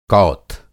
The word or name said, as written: कौथ